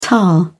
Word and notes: tar: British English

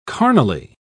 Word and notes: carnally: British English

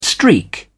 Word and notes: streak: British English

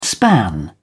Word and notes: span: British English

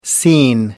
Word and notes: scene: American English

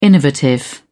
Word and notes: innovative: British English

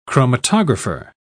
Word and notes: chromatographer: British English

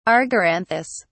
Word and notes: argyranthous: British English

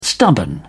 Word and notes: stubborn: British English

